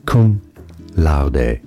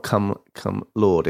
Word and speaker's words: cum laude: It's not cum cum lord. It's cum laude.